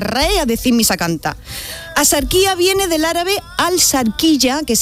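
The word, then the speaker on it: Axarquía: Escribimos asarquía con X, sí. ¿Vale? Pero ¿cómo lo pronunciamos? ¿Ajarquía? No, yo no conozco a nadie, asarquía.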